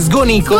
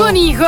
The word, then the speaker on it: Sgonico: In realtà il comune che non faceva parte della provincia di Oristano era Sgonico. – In realtà l'accento è diverso, è Sgonico. – Sgonico.